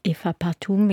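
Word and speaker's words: Ewa Partum: Ja, Ewa Pratum, ich habe den Namen vorhin falsch ausgesprochen,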